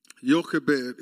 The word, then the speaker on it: Jochebed: Jochebed is how you pronounce her name.